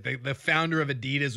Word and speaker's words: Adidas: I think it's Adidas. Is that right? – I think it is too. – Is that how you pronounce it? – Yeah.